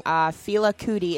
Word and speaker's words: Fela Kuti: Fela Kuti is how I'm going to say it. – Ooh, cooties! – I know, like cooties. – Ooh, cooties! – But spelled different.